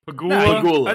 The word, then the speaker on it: Pegula: How do you say it again? – Pegula. I think you had it.